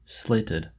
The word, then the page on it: slated: American English